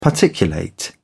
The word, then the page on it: particulate: British English